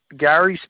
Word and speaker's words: Gary: Gary Simcox. Gary spelled with two R's.